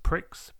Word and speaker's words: Pricks: And yes, just to be clear, it is pronounced pricks because we're childish like that.